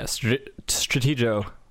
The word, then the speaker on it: Stratego: Yeah, Stratego sounds like they has that. – I love it. – Yeah, it's a hard G.